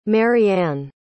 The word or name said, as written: mariann